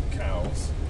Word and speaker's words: Cowes: There's a place called Cowes, not the animal, it's spelled slightly differently, and there's a place called East Cowes.